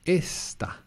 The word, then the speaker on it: esta: So not, remember, it's not esta. Esta is is. But here you're saying this, which is esta. – Esta. So stress on the E.